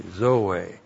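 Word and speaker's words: Zoe (ζωή): Z long O long E, Zoe.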